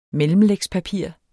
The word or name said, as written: mellemlægspapir